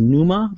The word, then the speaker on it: Pneuma: The second game, the free game that I got was Numa. That's spelled P-N-E-U-M-A.